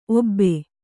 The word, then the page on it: ಓಬ: Kannada